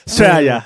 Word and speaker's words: Australia: 어, 역시 R 발음은 흐리는 게 맞아요. Australia.